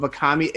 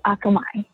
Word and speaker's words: Akamai: We have Akamai LLC. Did I say that right? – No, it's Akamai.